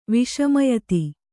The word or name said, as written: ವಿಷಮಯತಿ